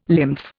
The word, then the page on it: lymph: Indian